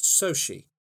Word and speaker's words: Xochitl: So her first name is spelled Xochitl, apparently pronounced Sochi. Sochi Gomez plays America Chavez.